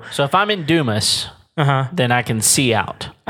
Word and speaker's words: Dumas: Go for it, Dumas. I pronounce that. – Oh, yeah. Everybody pronounces it the other way.